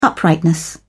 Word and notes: uprightness: British English

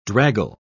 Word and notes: draggle: British English

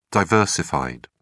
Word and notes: diversified: British English

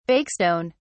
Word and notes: bakestone: British English

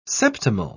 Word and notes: septimal: British English

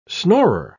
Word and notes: snorer: British English

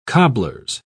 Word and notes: cobblers: British English

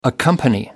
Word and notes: accompany: American English

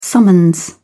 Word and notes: summons: British English

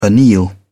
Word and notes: anneal: British English

